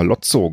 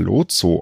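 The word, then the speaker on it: Lowtzow: Ich habe immer Lotzo gesagt, aber im Interview mit ihm habe ich dann gehört, dass Herr Lotso ausgesprochen wird.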